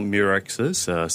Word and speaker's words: Mirxes: So Mirexis, six syllables, stand MIR, stand for microRNA, which is the technical thing we work on, very small type of RNA in our body.